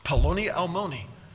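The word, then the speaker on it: Polonialmoni (פלוני אלמוני): That's exactly how you pronounce it. Polonialmoni.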